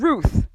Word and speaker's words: Ruth: I'm called Ruth. My name rhymes with the truth.